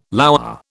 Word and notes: lour: British English

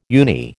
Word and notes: uni: American English

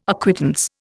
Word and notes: acquittance: Indian

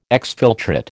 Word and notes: exfiltrate: American English

